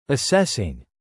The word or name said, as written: assessing